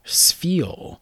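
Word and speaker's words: Spheal: The next card is, and I've always had a hard time pronouncing this Pokemon, Sfeel, which is spelled like a sphere, and seal, which is exactly what it is.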